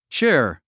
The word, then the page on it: cheer: tʃíə r; チア